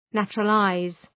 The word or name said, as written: naturalize